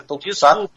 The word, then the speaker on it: Tokusatsu: Tokusatsu, não Tokusatsu. Apesar do S, é uma palavra japonesa, então fala-se Tokusatsu.